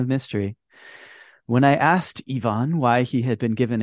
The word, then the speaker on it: Ivan: Oh, when I asked Ivan why, actually, Ayako pronounces his name Ivan. So maybe he even affected a, well, affect, I don't know. He pronounced his name Ivan, which is very continental now, isn't it? – Also not British though. British kind of say it like us, Ivan.